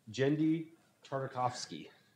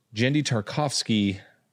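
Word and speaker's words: Genndy Tartakovsky: How do you say it? – Jendi Tartakovsky. – Jendi Tartakovsky.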